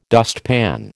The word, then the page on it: dustpan: American English